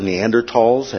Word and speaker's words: Neanderthals: Some of the names that you'll see is Neanderthals or Neanderthals, actually in the German pronunciation.